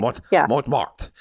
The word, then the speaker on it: Montmartre: I never know how to say Montmartre. Like, how do you say that? You ignore the last R and the E. – Yeah, you don't throw pronounce it away. Half the letters in French, you don't. Basically.